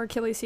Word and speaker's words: Achilles: Fun fact, Ender's Game ruined me for correctly pronouncing Achilles, because there's a character in it where his name is spelled Achilles, but it's pronounced Achille because he's French.